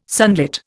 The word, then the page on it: sunlit: Indian